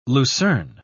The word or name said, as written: lucerne